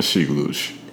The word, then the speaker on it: Sheik Louch: Louch. No. – Yeah, Sheik Louch. – Are you sure? – I promise you. – Okay, I've been saying it wrong my whole life.